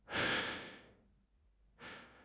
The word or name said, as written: jing1 chang2